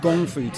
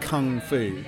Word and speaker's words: gong fu: So in China, there's a thing called gong fu tea. We've heard this word kung fu, which is a sort of less accurate pronunciation. Gong fu. Gong fu tea, again, it means skill and result, producing a great result through skill and experience.